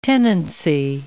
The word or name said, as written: tenancy